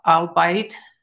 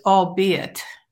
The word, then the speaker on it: albeit: So, the feedback came from him, you know, or if I mispronounced a word, like, you know, the word albeit, and I thought it was albite. – Yeah. Yeah. – It's albeit.